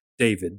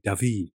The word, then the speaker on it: David: Because a lot of students, they pronounce it David, but it's Jacques-Louis David.